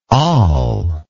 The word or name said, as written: al